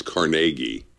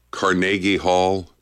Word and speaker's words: Carnegie: Now you're saying it wrong. It's Carnegie. Carnegie Hall. Carnegie Hall. Come on.